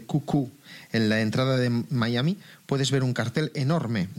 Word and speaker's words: Miami: Es el Miami, que se pronuncia aquí algo así como Miami.